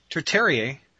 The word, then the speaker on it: tertiary: Could you briefly give some thoughts on the territory, territory? How do you say that word? Tertiary. Tertiary.